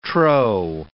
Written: trow

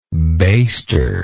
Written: baster

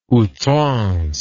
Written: outrance

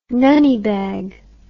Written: nunny bag

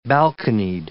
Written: balconied

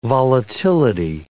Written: volatility

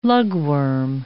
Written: lugworm

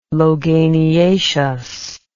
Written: loganiaceous

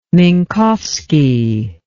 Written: minkowski

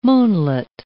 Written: moonlet

